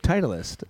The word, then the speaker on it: Titleist: Titleist. Titleist. – Titleist. – Everybody looks at that first, the T-I-T.